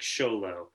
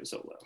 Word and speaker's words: Xolo: Though I should say that word is actually an Aztec word. And the language is Nahuatl, their language. So the traditional pronunciation would be more like Xolo. But for sort of our purposes, we are Trio Zolo.